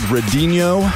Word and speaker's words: Redinho: Before that, we heard an artist called Redinho, R-E-D-I-N-H-O. I'm pronouncing that in what I think is the Brazilian way.